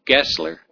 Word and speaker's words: Geisler: It's G-E-I-S-L-E-R. Anybody familiar with that name? Know how it's pronounced? Pardon? Geisler? Geisler. Okay, they use the long I.